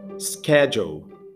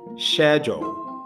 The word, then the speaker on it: schedule: É a palavra, de maneira americana, é falada schedule, schedule, ok? A maneira britânica, schedule, schedule. É a mesma palavra, mas com pronúncia diferente, ok?